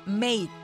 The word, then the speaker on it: mate: Cuando se pregunten cómo se dice compa, así los de allá de Inglaterra, mate. Se escribe mate, pero se pronuncia mate.